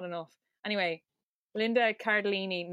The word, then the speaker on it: Cardellini: I was saying Linda Cardinelli. Her name is Linda Cardinelli? – Cardellini. – Cardellini.